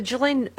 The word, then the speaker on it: Ghislaine: Can we just say for the 11th billionth time, the Ghislaine...